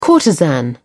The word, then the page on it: courtesan: British English